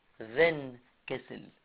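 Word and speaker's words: Windkessel: سموا الظاهرة دي في الكتاب عندكم Van Kessel effect. على فكرة هي بتتنطق كده Van Kessel، هي بالرغم انها بتتكتب W بتتنطق V، وطبعا دي كلمة ألماني، للناس اللي خدوا ألماني منكم عارفين الكلام ده في اللغة.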